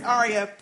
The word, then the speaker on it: Arya: Side note, my 8-year-old daughter's name is Arya, pronounced the same, and I listened to a bit of your last podcast when she was in the car with me.